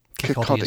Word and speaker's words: Kirkcaldy: First thing, am I right in hearing little tinkles and laughing around the Duchess of Kilcardi? – Kirkcaldy is how you pronounce it. It's spelt Kirkcaldy, but as my Scottish partner would tell you, it's Kirkcaldy.